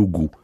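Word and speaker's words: gost: En occitan, c'est aussi le goût, mais ça s'écrit G-O-S-T. D'accord, tout simplement. Mais on le prononce le goût, enfin du moins chez nous.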